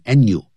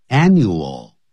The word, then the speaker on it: annual: annual 发音的时候也不能偷懒，念成 annual 就不对， annual 那个 L 的音呢在尾巴的时候念成 O，舌尖翘起来， annual，叫 girl，女孩子不要念 go叫 girl 啊，舌尖翘起来，像那欧，像欧洲的欧，舌尖翘起来。